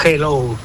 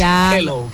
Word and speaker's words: hello: Y la segunda, dile a Elena que su dicción en castellano es la más perfecta que conozco, pero que cuando diga hello, que no pronuncie la J, que no es hello, que es hello, que la aspire la J y no pronuncie la J potente castellana.